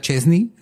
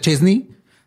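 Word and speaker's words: Szczęsny: Este güey que se apellida Chesney. O sea, se pronuncia Chesney, pero no se escribe S-Z-C-E-Z-N-I.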